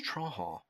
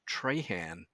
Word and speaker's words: Trahan: In South Louisiana, where the French people live, mm-hmm. it's Trahan, but I've been referred to as Trahan outside of that part of Acadia Parish.